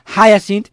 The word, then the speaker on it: Hyacinth House: Yo antes he dicho Hyacinth House, pero bueno, se pronuncia realmente, ya he subido a Jim Morrison, pronunciarlo Hyacinth, que la verdad es que queda mucho mejor.